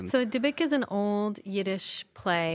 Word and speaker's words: Dybbuk: Dybbuk.